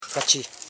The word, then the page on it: kachi: ka.chi; Koro